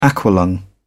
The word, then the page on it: aqua-lung: British English